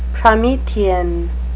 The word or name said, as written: promethean